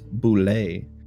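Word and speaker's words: Bulette: The official pronunciation is Bule. – Bule.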